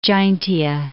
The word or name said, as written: Jaintia Hills